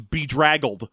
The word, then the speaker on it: bedraggled: And then someone told me, no, it's bedraggled.